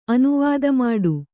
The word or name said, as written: ಅನುವಾದಮಾಡು